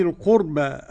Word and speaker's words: القربى: لا تعبدون إلا الله وبالوالدين إحسانا وذي القربى. عدم الغنة لخلف عن حمزة والغنة للباقي كله. – إحسانا وذي القربى. وذي القربى، القربى على وزن فعلة.